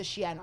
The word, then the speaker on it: Shein: Shein.